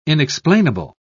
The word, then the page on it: inexplainable: British English